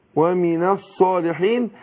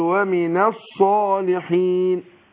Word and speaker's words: الصالحين: فأقول ومن الصالحين يبقى سقطت همزة الوصل واللام أضغمت في الصاد يبقى بنطق بعد النون ايه صاد اقول ومن الصالحين طيب الآية اللي بعدها اقول قالت ربي قالت رب أنى يكون لي ولد ولم يمسسني بشر.